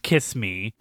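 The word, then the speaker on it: XX:me: So let's talk about our outro now, which our outro is Torikago by, it's spelled XX me, but it's pronounced Kiss me.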